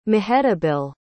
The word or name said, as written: mehetabel